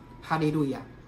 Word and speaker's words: 哈利路亚: 如果把以哈利路亚为主体，那是赞美神嘛，啊，哈利路亚，那本来是希伯来音啊，哈利路亚就是赞美耶和华啊，赞美真神，哈利路亚啊，本来是希伯来音，但是他用希腊文发音啊，就是哈利路亚啊。